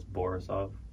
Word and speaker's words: Borisov: It's Borisov. – Borisov? – Or Borisov. It's the only thing that rhymes with my first name, Yaroslav Borisov.